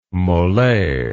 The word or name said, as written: mollet